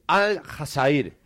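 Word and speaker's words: Al-Hazair (الجزائر): Si lo quieren decir bien, tienen que decir Al-Hazair. – Al-Hazair. – Me gusta. Que es la pronunciación en árabe, en el idioma.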